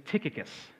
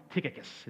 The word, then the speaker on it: Tychicus: So the first one, we'll go in the order they show up, is Tychicus. Now that's a name that, that's how it's pronounced. I know it's a weird one. Tychicus is how it's pronounced in the Greek.